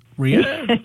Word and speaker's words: Rieu: Rhea, that's exactly right. Rhea.